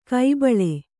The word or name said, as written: ಕೈಬಳೆ